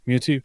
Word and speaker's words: Mewtwo: Well, I mean, it might seem stupid, but I always found having a Mewtwo. Is that how you say it in English? – Mewtwo, yeah. – Mewtwo.